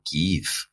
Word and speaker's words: Kiev: Kiev o Kiev, como los ucranianos nos han enseñado que se pronuncia. Sin embargo, voy a mantener la pronunciación que la hizo famosa y que era popular en Occidente hasta hace poco tiempo.